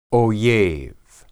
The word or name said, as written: oyeb